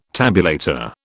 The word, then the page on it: tabulator: British English